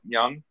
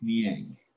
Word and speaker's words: Niang: I always thought it was, like, Young. – Oh, he's so wrong. It's Niang.